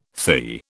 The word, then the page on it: THI: British English